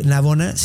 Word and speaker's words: Navona: eh, Navona, sí lo dije bien, ¿verdad? Sí, la